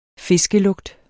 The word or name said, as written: fiskelugt